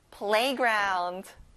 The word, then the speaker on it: playground: One more time, play에 강세를 넣어보세요. playground.